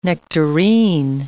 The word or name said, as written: nectarine